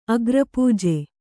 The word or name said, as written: ಅಗ್ರಪೂಜೆ